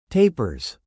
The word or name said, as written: tapers